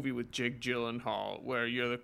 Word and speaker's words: Gyllenhaal: With Jake Gyllenhaal.